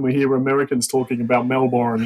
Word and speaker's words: Melbourne: I always love the way Americans say Melbourne. Yeah. We just say Melbourne.